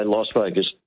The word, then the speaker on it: Las Vegas: The locals all say Las Vegas. The answer is it's actually Spanish, and the correct pronunciation of the L-A-S at the beginning would be something like Las with an R sound in the middle. But that is awkward for English speakers.